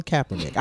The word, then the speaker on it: Kaepernick: It's Colin Kaepernick. – It's Colin Kaepernick.